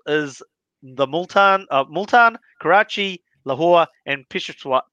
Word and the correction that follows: Peshawar: And I, have said that terribly, Taz. So how do you say that? Peshawar. Peshawar. I was like, I was practicing, I just couldn't get it right.